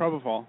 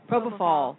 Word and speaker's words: Propofol: That propyl, how do you say yeah, it? Propofol. – Propofol.